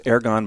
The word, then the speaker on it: Ergon: I think the Greek pronunciation is Ergon, but we're not going to beat up anybody for saying Ergon.